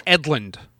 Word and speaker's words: Edlund: And in their newsletter, some Ben England drew it. I'm pronouncing that right. – I believe Ben Edlund. – Edlund, sorry. – I believe I've always heard pronounced it England too, because you just see like the rest of the letters are there, but there's right. A D where that first N should be.